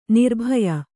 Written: ನಿರ್ಭಯ

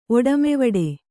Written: ಒಡಮೆವಡೆ

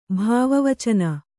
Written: ಭಾವವಚನ

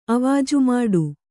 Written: ಅವಾಜುಮಾಡು

